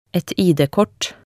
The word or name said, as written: et ID-kort